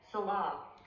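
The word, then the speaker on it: Selah: Sayla, right? – Salah. – Did you hear that? The Google pronunciation has more like a sound of S, Salah, Salah.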